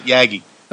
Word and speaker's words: Yagi: Yep, that's the multi-element beam, and that's known as Yagi antenna. The other guy pronounced it differently. I've always pronounced it Yagi.